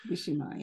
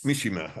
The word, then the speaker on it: Mishima: If I get the name right, Yukio Mishima. – Mishima, yes. – Mishima, okay.